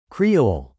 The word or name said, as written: Creole